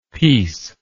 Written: pes

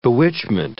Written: bewitchment

